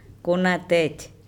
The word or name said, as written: konatEc